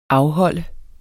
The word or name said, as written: afholde